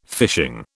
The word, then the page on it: fishing: British English